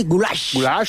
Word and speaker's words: goulash: Es el goulash, es pronunciado así, goulash.